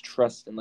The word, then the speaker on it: Trustin: I thought it would be like Tristan, but it's Trustin.